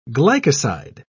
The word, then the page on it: glycoside: British English